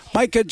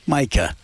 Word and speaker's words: Micah: And Micah Johnson, however you want to say that first name of his, because I kidded him about it, because it's spelled M-I-C-A-H. I said, is that Micah? No, he said, that's a bird.